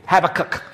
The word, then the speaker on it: Habakkuk: I want you to know about a little known prophet back then by the name of Habakkuk. Even when you pronounce it, it sounds like you're clearing your throat. Say it with me. Habakkuk.